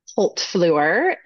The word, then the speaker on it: Holtbluer: I always like to pronounce my last name because I know it's kind of a doozy for folks. So Holtbluer is the way that you pronounce it. It's Dutch, actually.